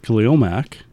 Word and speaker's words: Khalil Mack: I would say that I am in love with Khalil Mack.